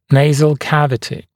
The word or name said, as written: nasal cavity